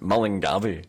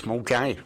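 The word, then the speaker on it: Milngavie: Also, when you look at Mulgai, how you spell it, it's M-I-L-N-G-A-V-I-E, and you would think Millen Garvey. Yeah. – You can always tell the English reporters. I love... I mean, nothing ever happens in Millen Garvey, but when it does, and they come on, and there was a major incident today in... – Millen Garvey. – Hang on, somebody's Scottish in the box here. What's that? How do you pronounce that? Mulgai, right. Looks Millen Garvey. No, it's Mulgai.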